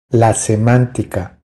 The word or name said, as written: La semantica